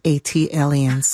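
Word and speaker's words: ATLiens: That is pronounced Atlians. – Ah, thank you. – Like Atlanta alien. AT alien. – AT alien.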